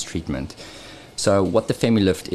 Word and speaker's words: FemiLift: Is the FemiLift, is that right? – Did I say that right? Yeah. Okay, so what is the difference between the FemiLift and the O shot?